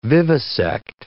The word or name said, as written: vivisect